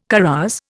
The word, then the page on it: carouse: Indian